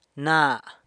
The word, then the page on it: na: sanlucasquiavini